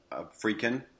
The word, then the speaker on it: Friedkin: I'm a really big fan of William Friedkin.